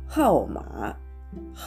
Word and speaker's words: 号码: これがハオマー、ハオマーという、えー、発音になりますがその、